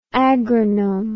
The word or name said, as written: agronome